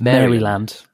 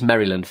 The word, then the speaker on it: Maryland: I always pronounce it as Maryland. Maryland. – Oh, and it's Maryland for them, is it? – Maryland.